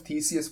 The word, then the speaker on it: Theseus: It sounds like a Ship of Theseus thing. I don't know how to pronounce it. Theseus? – Theseus.